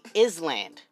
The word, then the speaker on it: island: Like, let's say you give someone island, I-S-L-A-N-D. Any person in their right mind is gonna say Island. You know what I'm saying? Is land. I-S-L-A-N-D. Bitch, why do you need an S in there if you're going to pronounce it island? Or better yet, why not rename it to I-L-A-N-D?